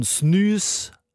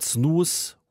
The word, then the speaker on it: snus: Das ist dann auch wieder schwedisch ausgesprochen. Snus anstatt Snus. U wird in Schweden wie Ü ausgesprochen. S-N-U-S.